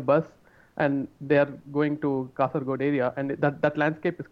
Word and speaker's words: Kasaragod: Fuck, am I pronouncing that right? Kasaragod, right? – Kasaragod. – Kasaragod, yeah.